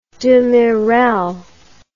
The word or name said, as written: demirel